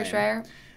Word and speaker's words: Shrier: Shrier.